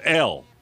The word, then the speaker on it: Elle: I kept pronouncing L-E or something. I didn't know it was called Elle.